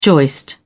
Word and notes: joist: British English

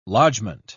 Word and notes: lodgment: British English

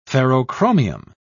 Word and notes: ferrochromium: British English

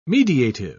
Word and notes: mediative: British English

